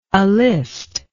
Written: alist

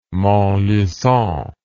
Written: montlucon

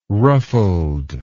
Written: ruffled

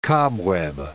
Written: cobweb

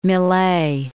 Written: Millay

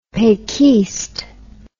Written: pequiste

